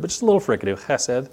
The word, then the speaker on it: chesed: It's chesed. The Hebrew word there is chesed. Often it's spelled C-H-E-S-E-D. Sometimes it's just spelled H-E-S-E-D. But either way, you're supposed to get a nice little fricative in there, a little ch. Don't clear your throat.